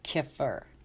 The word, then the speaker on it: kefir: First of all, I pronounce it incorrectly because that's what I'm used to. The actual pronunciation is kefir.